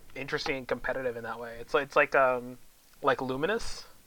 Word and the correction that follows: Lumines: or Lumens. I don't know. Lumines. There we go. That's how you pronounce that.